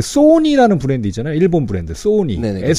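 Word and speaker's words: Sony: 발음이 소니는 네. – 전 세계 어디 가도 소니래요.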